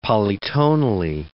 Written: polytonally

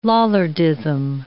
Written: Lollardism

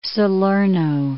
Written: Salerno

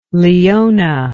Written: Leona

